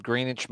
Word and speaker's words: Greenwich: And then the other, so in the wintertime, it's called Greenwich Mean Time, correct? I got that right. – Correct. Yes. – I said it right. I'm glad I've been saying it right this whole time.